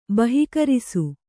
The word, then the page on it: ಬಹಿಃಕರಿಸು: bahih karisu